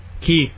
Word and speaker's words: quay: How do you say Q U? A-Y. – Key. – Anyone or everyone who pronounces it quay